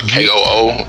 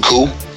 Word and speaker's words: Koo: K O O and cool.